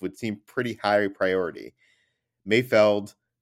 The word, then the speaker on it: Mayfeld: Highlight of my week, I noticed that everyone pronounced Bill Burr's character as Mayfield, but it's definitely Mayfeld.